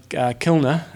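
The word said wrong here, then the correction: Kienle: I say Kiln, and it's supposed to be Keenler. Keenler. Sebastian Keenley.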